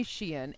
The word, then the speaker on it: Shein: It's definitely Shein, and you went to Scotland for too long, Eileen, and you're like, it's Shane.